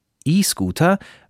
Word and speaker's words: E-Scooter: Die Stadtverwaltung von der Stadt Mainz sagt, E-Scooter müssen ab jetzt auf diesen Parkplätzen stehen. E-Scooter spricht man E-Scooter.